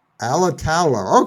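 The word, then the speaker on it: Alatalo: Alitalo, okay, just like it looks, okay.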